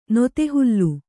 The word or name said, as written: ನೊದೆಹುಲ್ಲು